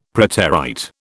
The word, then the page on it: preterite: British English